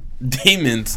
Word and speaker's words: Demons: Demons. D-E-M-O-N-S.